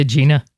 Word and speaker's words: Agena: Is it called Agena? I always say Agena. Is Agena. – That the right pronunciation? Yeah. – Yeah, Agena.